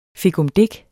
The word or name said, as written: fikumdik